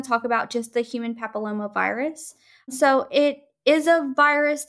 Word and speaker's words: papillomavirus: That's human papillomavirus.